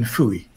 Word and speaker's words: Fowey: there's a fishing village called Fowey, pronounced in American Fooey.